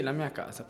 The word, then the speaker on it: La mia casa: Sí, La mía casa, perfecto.